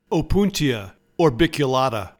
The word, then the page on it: Opuntia orbiculata: O-POON-tee-a or-bic-u-LA-ta